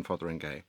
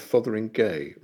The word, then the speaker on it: Fotheringhay: I can't help but notice that you adopt, I have to say, the minority pronunciation of Fotheringay rather than Fotheringhay. Now, I don't want to start something running again, as we've had with cuneiform and various other Qumran writing gates, but Fotheringay, not Fotheringhay.